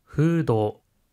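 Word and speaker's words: フード: 하이, 푸드는 F 발음 때문에 푸도라고 발음합니다.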